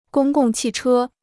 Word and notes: 公共汽车: gōng gòng qì chē

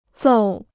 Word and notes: 揍: zòu

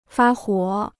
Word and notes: 发火: fā huǒ

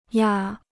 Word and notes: 压: yā

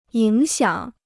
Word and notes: 影响: yǐng xiǎng

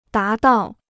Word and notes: 达到: dá dào

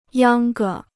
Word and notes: 秧歌: yāng ge

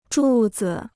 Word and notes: 柱子: zhù zi

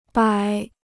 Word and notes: 摆: bǎi

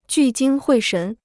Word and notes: 聚精会神: jù jīng huì shén

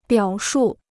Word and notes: 表述: biǎo shù

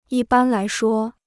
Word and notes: 一般来说: yī bān lái shuō

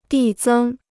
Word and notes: 递增: dì zēng

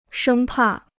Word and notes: 生怕: shēng pà

